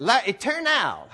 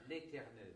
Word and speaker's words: l'éternel: And it's basically translated, l'éternel. Is that a good pronunciation? – L'éternel.